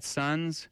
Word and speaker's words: Suuns: So before that, we had Sons with their track called Unknown from their latest album called Hold Still. Sons is a Canadian rock band, and I should say it's spelled S-U-U-N-S for those that are going to look up that track or the band.